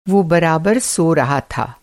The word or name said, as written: बराबर